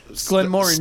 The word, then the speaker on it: Glenmorangie: I'm just saying, we mispronounced Glenmorangie 700 times. – Glenmorangie. – Even after we listened to it. – Oh, the whole episode. – Yeah, just awful. – Oh, yeah. – So... – Glenmorangie.